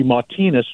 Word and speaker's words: Martinez: And the primary names in his family are Martinez, which would be Martinez just about anywhere else in the country, but mm-hmm. we tend to use the French, the Gallicized pronunciation in New Orleans. Baquier, B-A-Q-U-I-E, with the accent ague over the E.